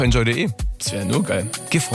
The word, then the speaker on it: Gifhorn: Habe ich es richtig ausgesprochen? Gifhorn, ja.